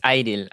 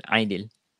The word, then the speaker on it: Aidil: It's Aidil, Aidil. – Aidil, okay. – Mm-hmm. Like Aidil Adha, I think. I don't know if that's how you guys call it.